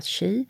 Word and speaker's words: qi: Så QI och uttalat chi, det är livsenergin.